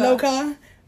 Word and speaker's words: loca: you Luca, um, but Lo- you said it wrong. Loca. – Loca.